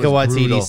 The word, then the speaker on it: Taika Waititi: But it, like overall, like again, like, and even Taika Waititi, is that how you pronounce his name? Yeah, Taika Waititi.